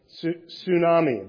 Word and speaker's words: tsunami: So tsunami, the T is silent.